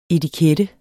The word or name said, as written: etikette